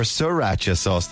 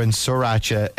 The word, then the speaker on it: sriracha: My other half swears the first R in sriracha is silent.